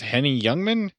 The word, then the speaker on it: Henny Youngman: There is a classic comedian that some folks should recognize as, I believe it's Henny Youngman. – Yes. Uh, that's how you pronounce his name.